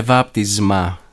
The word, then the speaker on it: βάπτισμα: Ήθελε να λέμε «βάπτισμα», να ακούγεται σαν «ζήτα», όχι σαν «σίγμα», «βάπτισμα».